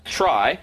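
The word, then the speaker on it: trie: So it turns out that this data structure, called a try, short for retrieval, but pronounced try, is meant to be a play on words of tree,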